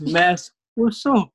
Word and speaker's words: Mads Rossau: It's Mass Rossau. – Rousseau. – Yeah, like a French, kind of a French accent.